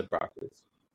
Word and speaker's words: Broccoli: And for a really long time, I always assumed that they pronounced their name Broccoli or something, different than the vegetable, but no. – No, it's the Broccoli's.